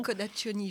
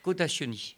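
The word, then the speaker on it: Codaccioni: Alors j'ai comme support un livre de Vanessa Cadacioni, je n'ai pas écorché son nom, hein, c'est bon. – Cadacioni, je crois, oui. – Cadacioni.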